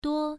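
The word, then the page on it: duo: tone 1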